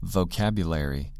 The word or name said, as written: vocabulary